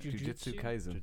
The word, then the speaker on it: Jujutsu Kaisen: Did I say it wrong? – No, you said it right. – Jujutsu Kaisen.